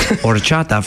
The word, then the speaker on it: horchata: Now, the H is silent, always silent. Horchata, for those not familiar, is considered Mexican rice water, which doesn't sound too appealing, but it is really, really tasty.